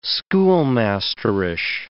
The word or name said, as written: schoolmasterish